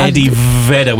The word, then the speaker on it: Eddie Vedder: Eddie Vedder with a V.